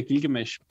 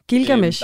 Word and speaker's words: Gilgamesh: Udtaler jeg det egentlig rigtigt, eller skal man lægge trykket anderledes? – Jamen, det er et godt spørgsmål. Altså, jeg siger Gilgamesh. – Gilgamesh.